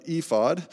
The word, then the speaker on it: ephod: On top of this, we have what is called an ephah. There was much discussion uh, before the service what is the correct pronunciation of ephod. And we're going to go with what I just said.